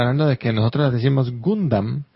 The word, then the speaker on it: Gundam: Y por último, mi fanatismo por Gundam, más en Puramoderu, me hace pedirles esta aclaración. Su pronunciación es Gundam, con A.